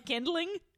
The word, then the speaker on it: kindling: Is it not spelled kindling? – I don't know how it's spelled, but I know it's pronounced. It's spelled kinlan. Oh, what did you God. Say? Kinkling? – Kindling. K-I-N-D-L-I-N-G.